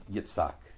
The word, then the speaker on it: Yitzhak: It's usually pronounced Yitzhak.